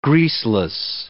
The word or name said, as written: greaseless